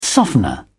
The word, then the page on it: softener: British English